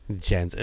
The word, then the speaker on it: Jens: J-E-N-S. – Jens. Now Jens, but it's pronounced Jens.